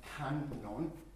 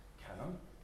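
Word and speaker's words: Kannon: Guanyin in Japanese, this character and this character in Japanese are pronounced Kanon. Kanon.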